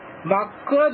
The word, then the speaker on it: MAC: で、 LAN カッコイーサネットに接続する機器には製造時にメーカーによりマックアドレスと言います。これ MAC と書いてマックアドレスと読みますという機器固有のアドレス、これ 48 ビットなんですけれども、これが割り当てられています。